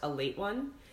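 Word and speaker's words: Ale-8-One: So there's like one in particular that caught my eye that I already consumed is a ginger soda out of Kentucky called A Late One, but spelled Ale 81, but it's pronounced A Late One.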